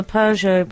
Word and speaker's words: Peugeot: On the Peugeot pronunciation question, where does the R come from that most people squeeze in? Peugeot. I always ask if Peugeot has an R in it to anyone who says it incorrectly.